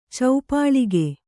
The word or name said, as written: ಚೌಪಳಿಕೆ